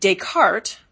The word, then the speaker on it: Descartes: So, for example, you have Descartes, and Descartes, the spelling ends in S.